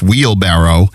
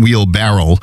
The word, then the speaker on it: wheelbarrow: Wheelbarrow, which is the proper pronunciation of it. It's not wheelbarrow.